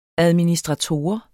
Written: administrator

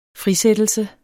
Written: frisættelse

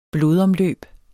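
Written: BLODOMLØB

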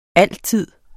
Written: altid

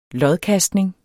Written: lodkastning

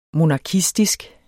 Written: monarkistisk

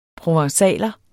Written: provencaler

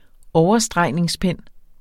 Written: overstregningspen

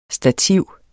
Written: Stativer

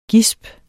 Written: gisp

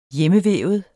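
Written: hjemmevævet

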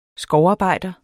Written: skovarbejder

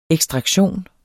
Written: EKSTRAKTIONEN

